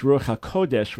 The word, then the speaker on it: Ruach HaKodesh: And it's Ruach HaKodesh with the accent on the first syllable, not the second syllable.